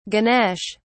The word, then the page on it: ganesh: British English